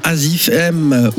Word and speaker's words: AZIFM: avec un single signé par Azif M ou Azi FM. Vous prononcez comme vous voulez, c'est écrit AZIFM.